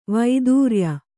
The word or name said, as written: ವೈದೂರ್ಯ